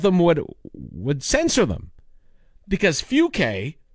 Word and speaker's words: Fucke: The town's name is spelt F-U-C-K-E, Fuke.